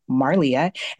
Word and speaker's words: Marlia: My name is spelled, well, my name is Marlia, and it's M A R L I A, Marlia.